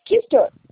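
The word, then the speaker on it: Küster: Dalila Meira Custem. – Mister. – Nome isso, diferente, bonito. – Escreve KU, né? Mas é a pronúncia é Kister.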